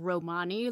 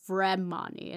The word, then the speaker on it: Vremyonni: It's spelled V-R-E-M-Y-O-N-N-I. Vremani, and not like the actual people of Romania, Romani. – No, it is not. No, it's Vremani.